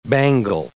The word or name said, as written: bangle